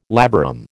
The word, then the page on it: labarum: American English